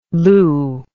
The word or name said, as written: lou